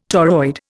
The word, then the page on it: toroid: Indian